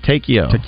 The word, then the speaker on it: Takeo: Also, over the weekend, too, it's Takeo Spikes, right? That's how you say it. Takeo. Always, you know, Takeo, Takeo.